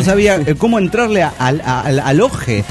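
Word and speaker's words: Ojje: Soy del pueblo Santiago de Oje, que se pronuncia Oje como ojo.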